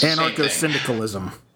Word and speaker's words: anarcho-syndicalism: It's anarcho-syndicalism.